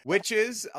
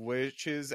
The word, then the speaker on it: wicce: The word witch comes from the word Wicca, also witche, I believe is how I say that. It's like, it was like leche, witche. It's spelled W-I-C-C-E instead of W-I-C-C-A.